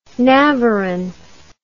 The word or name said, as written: navarin